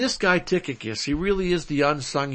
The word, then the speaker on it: Tychicus: How about Ephesians 6:21, Tychicus? That's how you pronounce that, Ephesians 6:21, Tychicus,